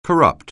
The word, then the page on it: corrupt: anglais